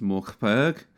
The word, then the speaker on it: Mörk Borg: And uh, a lot of these were themed, or rather, this entire thing is themed on a RPG system called uh, Mörk Borg, or I believe it's pronounced Morkberg.